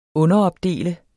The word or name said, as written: underopdele